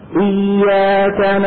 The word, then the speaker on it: إياك: الياء من كلمة إياك مشددة غير شديدة. – لا، مشددة يعني مضاعفة، يعني تتألف من ياءين. غير شديدة يعني لا ينحبس الصوت عند النطق بها. بعض المبتدئين أو المبتدئين قد يقولوا إياك نعبد وإياك، شو بيكونوا عملوا؟ شدوها، يعني جعلوها شديدة.